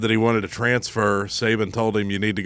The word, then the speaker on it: Saban: Nick Saban.